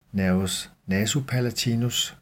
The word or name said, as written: n. nasopalatinus